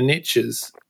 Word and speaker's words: niche: but you'll love it because it's based on American pronunciation, and I'm sure you're familiar with it. The riches are in the niches. – So I say niche, and it does my head in.